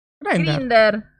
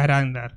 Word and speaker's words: Grindr: Grinder, Grinder, ¿cómo se dice? – Grinder. Grinder, qué sé yo. Yo eh, le digo Grinder. Yo digo Grinder porque así le decía a la faraona que fue donde escuché por primera vez que existía Grinder, así que.